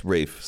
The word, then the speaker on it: Ralph: And that guy's name is Ralph Fiennes, but it's pronounced Ray Fiennes? – Yes, Rafe.